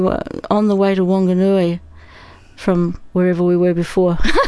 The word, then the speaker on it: Whanganui: For our overseas listeners, that's Whanganui. – Yeah, Whanganui, Māori pronunciation.